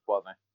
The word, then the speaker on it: Boivin: I don't even know if I pronounce his name right, but would it be Boivin? How would you pronounce? – Yeah, in French, Boivin.